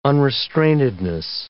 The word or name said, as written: unrestrainedness